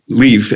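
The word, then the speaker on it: Lieff: So it's J-O-N, John Leaf, L-I-E-F-F. J-O-N, no one knew John until John Stewart, I think, and then everything was always had an H.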